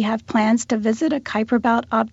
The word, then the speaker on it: Kuiper: It's headed out into the, how do you pronounce it, Kuiper Belt? K-U-I-P-E-R? – Yeah, I call it the Kuiper Belt.